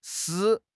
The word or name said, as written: shi3